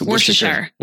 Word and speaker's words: Worcestershire: How do you say this word? It's Worcester. – It's Worcestershire. Worcestershire sauce.